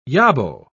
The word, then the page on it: yobbo: British English